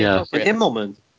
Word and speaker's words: Immelmann: An Immelmann.